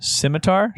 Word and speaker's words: scimitar: Scimitar. – How is that pronounced? Scimitar? – Yep. – Scimitar. – Scimitar. – Scimitar. I've been wrong all this time.